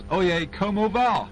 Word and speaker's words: Oye Como Va: It's called The Backseat of My Car, and taking us up to 7:30 and station ID time, here's Oye Como Va. I actually said it right.